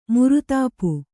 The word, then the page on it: ಮುರಿತಾಪ: Kannada